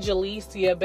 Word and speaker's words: Jalyssia: You can add me as a friend, Jalecia Bell. That's spelled J-A-L-Y-S-S-I-A. Last name, B-E-L-L.